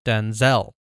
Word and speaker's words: Denzel: Though both are spelled the same, his dad's name is pronounced with stress on the first syllable, Denzel, whereas the actor's name is pronounced Denzel. Like, he stresses the Zell part.